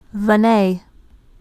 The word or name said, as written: Veney